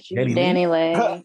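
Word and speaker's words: DaniLeigh: Danny Lay. How do you pronounce that? Oh, Danny Lay.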